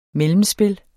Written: mellemspil

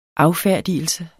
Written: affærdigelse

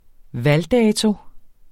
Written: valgdato

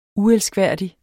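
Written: uelskværdig

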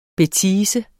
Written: BETISE

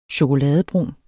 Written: chokoladebrun

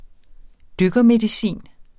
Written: dykkermedicin

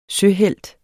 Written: søhelt

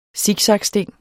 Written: siksaksting

